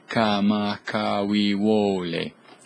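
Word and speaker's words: Kamakawiwole (Kamakawiwoʻole): We're going to listen to Israel Kamakawiwole.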